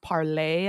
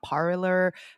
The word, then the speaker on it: Parler: I believe it's actually pronounced Parlay and not Parler, pre all of that trash.